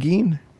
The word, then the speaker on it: Gein: Is that how you pronounce it, Gein? – It is. – Yeah. Mackenzie Mizell is writing a Ed Gein musical with someone.